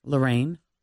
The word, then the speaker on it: Lerynne: How do you pronounce that name? – Spell it. – LERYNNE. – Lorraine.